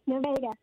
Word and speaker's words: Nevada: Am I saying that right? – Yes. Nevada. Yes, Nevada.